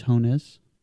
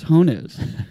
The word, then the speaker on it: Jones: J in Spanish is H, Jones.